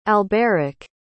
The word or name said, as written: alberic